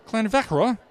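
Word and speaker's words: Llanfrechfa: To get you started, the double L is a soft CL sound, and the F's are gentle V's. And before I try to pronounce it, I'll spell it for you. It's L-L-A-N-F-R-E-C-H-F-A. I love challenges like this. And if I'm right, the town is pronounced Clanvechra, and the tasting is Monday night at the Gate at Clanvechra.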